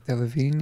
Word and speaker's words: Delevingne: Cara Delevingne? Is that how you say it? – It is now. – Delevingne, yeah. – I don't know how to say it. – Delevingne.